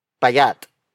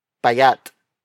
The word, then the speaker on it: payat: Payat. Payat. Payat. Payat is spelled as P-A-Y-A-T.